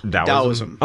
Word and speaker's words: Taoism: In order, or actually, they'd even kind of say the T as a silent and almost pronounce it with a D, Taoism.